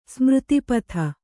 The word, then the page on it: ಸ್ಮೃತಿಪಥ: Kannada